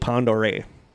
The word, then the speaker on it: Pend Oreille: And I'm glad I looked up how to say that because it looks like Pend Oreille. But it's French, yeah. – Okay. – It's pronounced Lake Pend Oreille.